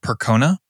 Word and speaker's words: Percona: One was a product called Percona. I believe I'm pronouncing that correctly. Mm-hmm. P-E-R-C-O-N-A.